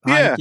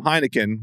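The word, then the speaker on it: Heinicke: Taylor Heineke, am I saying it right? – Yep, Heineke. Yeah. I mean, Simmons started calling him Heineken, which feels disrespectful to me.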